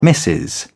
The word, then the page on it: Mrs. Humphrey Ward: British English